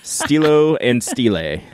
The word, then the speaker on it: Stilo and Stile: Did I do that okay? Like Stilo. Oof, yeah. So you know us and our pronunciations. We don't have Federico on this show. – Stilo but, Stile. – Stilo and Stile. This is Stilo and Stile.